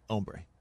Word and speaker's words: hombre: Have him on more, and you named him a Hombre fan. – Yep. The H is silent. Hombre. Spanish.